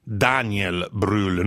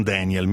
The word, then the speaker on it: Daniel Brühl: Però si pronuncia Daniel Brühl, non Daniel, mi raccomando, perché anche lui è austriaco barra tedesco, mi raccomando.